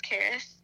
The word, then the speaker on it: Xaris: It's called Under the Willow, and it's by me, and my name is spelled with an X. It's Xaris, which is kind of weird, but it's Xaris.